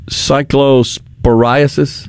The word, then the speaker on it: cyclosporiasis: cyclosporiasis? – That's correct.